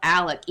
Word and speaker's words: Alex: And he would he called himself Alec even though it's spelt with an X.